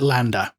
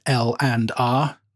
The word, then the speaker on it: LANDR: So we're talking about things like, now I always call it Landa, but apparently you're supposed to pronounce it L and R, but I'm going to call it Landa because that's otherwise I'll never remember.